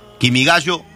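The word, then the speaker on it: Kimigayo (君が代): Kimigayo, también es así como se pronuncia.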